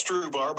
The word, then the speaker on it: Strubhar: Yep, we say it's Strubhar, but same difference. – Strubhar, S-T-R-U-B-H-A-R, B-R-I-A-N.